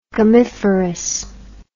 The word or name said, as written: gummiferous